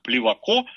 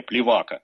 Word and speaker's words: Плевако: Однако избрал юридическую карьеру, стал вскоре помощником присяжного поверенного, потом сам стал присяжным поверенным, работал с таким знаменитым адвокатом, как Плеваков, Такое ударение, кстати, дает Википедия. Я сам и все мои знакомые всегда делали ударение «плевака».